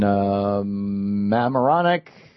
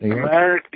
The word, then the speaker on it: Mamaroneck: In Mamaroneck, that's not how you say it. – Mamaroneck, New York.